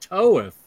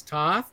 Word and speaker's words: Toth: And who the hell knows it's pronounced Toeth? It's not Alex Toth.